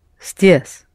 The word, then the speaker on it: здесь: The soft sign in the first word indicates that S should be pronounced soft. So, здесь. – Да. Здесь. You pronounce S very soft.